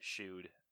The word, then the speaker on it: Chud: Yeah, with the way Mike was saying it, I thought it was like Jude or something, but Chud.